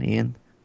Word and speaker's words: Iain: And the whole time it's just you got that going on, but then you also got how do you pronounce his name? Ian, right? I-A-I-N, Ian.